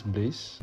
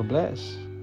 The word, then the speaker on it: BLXSS: It's bliss or bless. You can, I mean, if you write it down, you should write BLXSS.